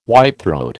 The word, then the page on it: whitethroat: American English